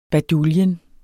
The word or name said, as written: baduljen